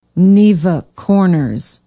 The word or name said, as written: Neva Corners